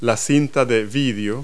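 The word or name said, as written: videotape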